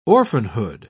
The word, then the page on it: orphanhood: British English